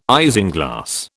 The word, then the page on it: isinglass: British English